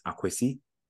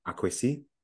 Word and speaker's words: Akwesi: So, for example, my proper name is if you're deep in the forest, is Akwesi, is an A in front of the K, okay. Right? Akwesi. But you know, in other areas, it just being Kwesi, and on the coast, it's Kwesi spelled with an E rather than an A, so it's K W E S I. – Okay. – And so there's slight phonetic, you know, nuances depending on location, right?